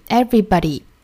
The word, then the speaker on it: everybody: 后面的这个 everybody 也是一样的，它的尾音的话呢，应该是 e 的发音， everybody， everybody。